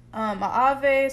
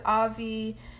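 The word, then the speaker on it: AAVE: Whether that is Ave, some people pronounce it Avi.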